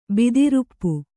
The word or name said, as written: ಭೂತರೂಪ